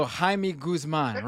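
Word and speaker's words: Jaime Guzmán: Yeah, James Guzman. – Yeah, we don't pronounce the J in Spanish. I forgot. I'm sorry. So Jaime Guzman.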